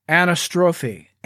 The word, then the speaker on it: anastrophe: That word, we pronounce that in Greek, anastrophe.